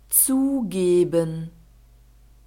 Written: zugeben

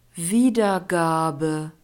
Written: wiedergabe

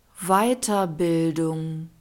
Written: weiterbildung

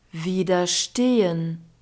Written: widerstehen

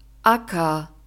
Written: acker

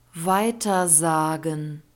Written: weitersagen

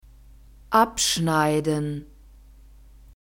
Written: abschneiden

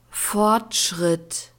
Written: fortschritt

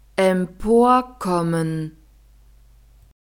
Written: emporkommen

